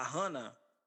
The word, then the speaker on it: Jana: Que, inclusive, a menina, a azul, que é a Hannah, que a gente chama ela de Jana, porque o nome dela se escreve Jana, mas falam que o nome dela é Hannah, então para mim o nome dela é Jana, tá?